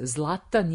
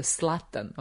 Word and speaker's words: Zlatan: Šveđani nemaju Z, oni S, apsolut, sve što je naše Z, Zlatan je Z, Slatan, oni ne znaju Z da izgovore i nema ga, Zeta ne postoji kao u jeziku švedskom. Odjednom žena Šveđanka 100% se zove Zaga, nije Saga.